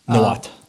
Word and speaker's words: Nahuatl: Nahuatl.